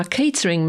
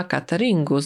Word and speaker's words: catering: Copper to po brytyjsku w slangu gliniarz albo ochroniarz, a catering manager to szefowa cateringu. Zwróćcie uwagę na wymowę słowa catering. My po polsku mówimy catering, a tutaj mamy catering manager.